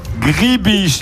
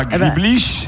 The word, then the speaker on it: gribiche: Alors, c'est la sauce gribiche et non pas griblish.